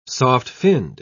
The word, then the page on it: soft-finned: British English